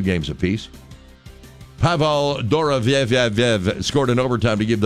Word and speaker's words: Dorofeev: Dorofeev is how you would properly pronounce his last name, Bob.